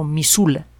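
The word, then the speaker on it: Missoula: Hubo un lago en la última gran glaciación, el lago Misula, se escribe Misoula, en, lo que ahora es la frontera de Estados Unidos con Canadá.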